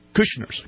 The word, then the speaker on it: Kushners: Am I pronouncing that right? – Oh, yeah. – Anyway, I am pronouncing that right, name. Right? Kushners.